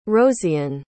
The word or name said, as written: rosiyn